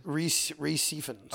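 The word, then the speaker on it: Rhys Ifans: Reese Fans, isn't it? Refons? How do you say it? – Reese Efans. – Oh, it's Reese? – Reese, yeah.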